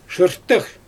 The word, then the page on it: ʒɯrtɯx: Xyzyl